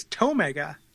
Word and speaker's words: Tomega: Well, it's Tomega. – Yep. No. – He's from Station Omega, though. – Hmm. – But his name rhymes with it.